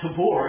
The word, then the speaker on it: Tabor: That is Mount Tabor, is how the Hebrews pronounce it. Spelled T-A-B-O-R. We've got a street named that not far from here, too. Tabor, I think, is the way most of us pronounce it. But Tabor is how it ought to be.